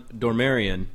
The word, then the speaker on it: Dormarian: Because on this, on Dormarian, and I said that right? – You did.